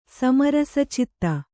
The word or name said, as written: ಸಮರಸಚಿತ್ತ